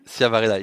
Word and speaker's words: Chiavarella: Can you pronounce that for me, Dorian? Did I say that right? – Chiavarella, it's Italian.